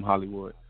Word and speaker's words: Holliewood: At I Am Hollywood.